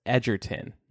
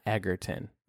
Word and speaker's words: Egerton: Our social media manager, Jules, says it's pronounced Edgerton, not Egerton. I'm sorry. Edgerton. And if that's wrong, blame Jewel.